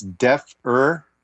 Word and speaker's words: deafer: It actually is deafer, the 80s lingo.